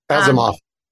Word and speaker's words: Asimov: There's a famous science fiction writer, Isaac how do you say his last name? – Asimov. – Asimov, thank you.